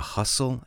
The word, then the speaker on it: hustle: Now, how do you spell hustle? H-U-S-T-L-E. H-U-S-T-L-E. The T is silent. How do you spell bustle? Well, it's B-U-S-T-L-E.